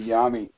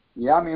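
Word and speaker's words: Yom: The word is pronounced Yam. – Yam? – Yam. – Okay. – It's not Oh, thank you. I do. You know, when I do messages, I speak them. And when I pronounce it wrong, that's how they spell it. So I need to learn to pronounce things, especially. – If you thank try one, yeah, not a problem. If you write it out, it's Y-O-M. – Okay. – Okay. Yam. Yam. Like yam.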